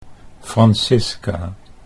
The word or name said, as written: Francisca